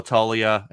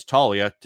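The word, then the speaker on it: Toglia: Before we hit our final player here, a couple guys from Colorado, Michael Talia, and it's, we found out it's Talia. Just take the, just don't pronounce the G at all in his name, but he also gets called Togs by his teammates.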